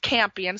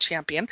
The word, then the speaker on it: Champion: $55, Wednesday, June 27th at 7 p.m., Fern Campion, or some people, it's spelled Champion, but pronounced Campion. Some people call it Champion.